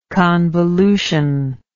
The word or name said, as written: convolutionary